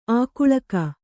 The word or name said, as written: ಆಗಳಿಕೆ